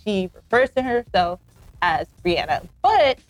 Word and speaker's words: Rihanna: But I had to make sure that I'm saying her name right, because for years, many people like myself would call her Rihanna, when in actuality, it's Rihanna.